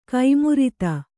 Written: ಕುಮಾರಿತಿ

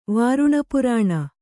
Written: ವಾರುಣಪುರಾಣ

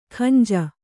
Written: ಗಂಜಿ

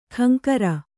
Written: ಖಂಕರ

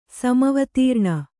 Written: ಸಮವತೀರ್ಣ

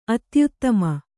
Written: ಅತ್ಯುತ್ತಮ

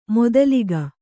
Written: ಮೂದಲಿಕೆ

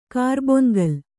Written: ಕರ್ಬೊನ್ಗಲ್

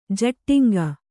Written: ಝಟ್ಟಿಂಗ

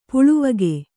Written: ಪಾಲ್ವಕ್ಕಿ

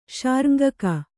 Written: ಶಾರ್ಙ್ಗಕ

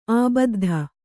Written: ಆಬದ್ಧ

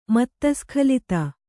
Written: ಮತ್ತಸ್ಖಲಿತ

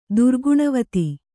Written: ದುರ್ಗುಣವತಿ

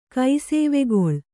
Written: ಕೈಸೇವೆಗೊಳ್

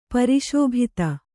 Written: ಪರಿಶೋಭಿತ

